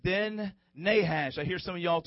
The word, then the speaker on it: Nahash: Say it again, Nahash.